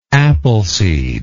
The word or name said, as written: appleseed